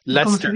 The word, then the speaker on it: Leicester: And and I do think um, when we hear stuff like laces I don't know how to pronounce that. Laces Leicester. – Leicester. Just, say, just um, say Leicester. Leicester. – Just say Leicester.